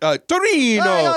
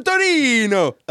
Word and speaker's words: Torino: Turin. We made that city sound more Italian than the actual way the Italians pronounce that city. – Hey, where do you live? Turin. Great. Torino. We're going to Torino.